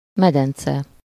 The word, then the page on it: medence: /ˈmɛdɛnt͡sɛ/